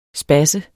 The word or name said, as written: spasser